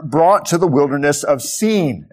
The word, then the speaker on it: Sin: And that's how you should pronounce that, lest you confuse it with the wilderness of sin. It's true that Israel sins in the wilderness, but this is the wilderness surrounding Sinai, and that's why it is spelled S-I-N.